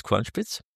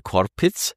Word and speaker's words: Kornspitz: Also auf der ganzen Welt heißt der Kornspitz Kornspitz. Nur in 2 Gegenden ist es anders. Die in portugiesisch sprechenden Ländern, die können keine 4 Konsonanten hintereinander aussprechen. Ja. Haben wir 2 Buchstaben gestrichen, dort heißt er Korpitz.